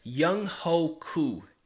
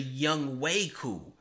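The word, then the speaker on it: Younghoe Koo: His name is actually Young Wei Koo, but I didn't know that.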